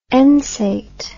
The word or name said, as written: ensate